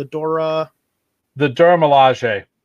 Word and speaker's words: Dora Milaje: The Dora Milaje.